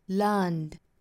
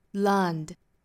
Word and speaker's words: learned: 所以这里面我们应该是读 learned, learned。那一说到加出来这个音读的发音呢，那我们中国同学就很多会把它读得过重，读成 learned, learned, 读成一个的发音。